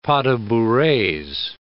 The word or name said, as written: pas de bourrees